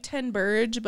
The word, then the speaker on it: Ten Berge: It's spelled like Ten Berge, but it's like you have to like it's in the throat.